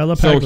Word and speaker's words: LaPaglia: And even Tony LaPaglia, or I cannot pronounce his name. – Yeah, LaPaglia.